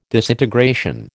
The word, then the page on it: disintegration: American English